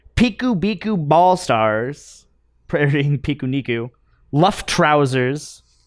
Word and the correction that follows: Luftrausers: Not sure if you pronounce that differently. – Luftrausers. – Instead of Luftrausers.